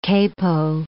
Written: capo

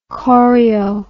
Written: chorio

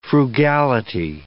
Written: frugality

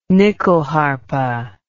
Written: nyckelharpa

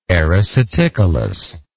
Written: ericeticolous

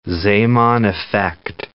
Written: Zeeman effect

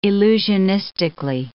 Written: illusionistically

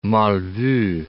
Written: mal vu

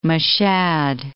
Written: Mashhad

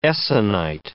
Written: essonite

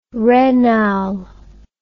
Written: raynal

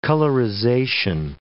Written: colorization

